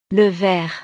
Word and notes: le ver: frances

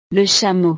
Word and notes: le chameau: frances

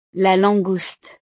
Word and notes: la langouste: French

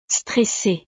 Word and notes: stresse: frances

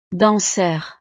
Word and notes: danseur: frances

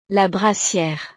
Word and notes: la brassiere: frances

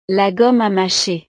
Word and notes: la gomme a macher: French

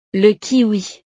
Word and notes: le kiwi: frances

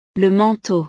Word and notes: le manteau: frances

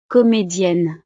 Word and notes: comedienne: frances

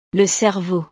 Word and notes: le cerveau: frances

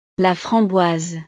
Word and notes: la framboise: French

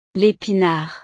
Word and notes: l'epinard: frances

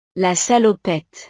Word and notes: la salopette: frances

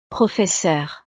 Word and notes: professeur: frances

